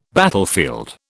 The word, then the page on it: battlefield: British English